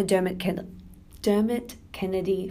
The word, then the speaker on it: Dermot Kennedy: I am a Dermot Kennedy fan. There we go. Said it correctly.